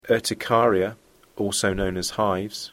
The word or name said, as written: urticaria